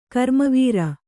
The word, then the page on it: ಘರ್ಮವಾರಿ: kannada